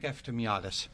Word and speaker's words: Eftimiades: Hi, Nick Eftimiadis.